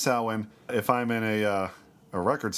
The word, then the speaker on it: Samhain: People probably heard the word Samhain as mispronounced in the Halloween movies, but that's not how it's pronounced.